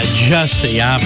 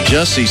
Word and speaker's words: Jussie: Yeah, Jussie. – Jussie sounds like fussy. Rhymes with, sounds like, reminds me of.